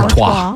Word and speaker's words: Artois: Because, how do you say, Stella Artois? I always just say Stella Artois. – Artois.